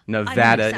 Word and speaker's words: Nevada: Nevada is the proper way to say the state. Nevada, not proper, Nevada.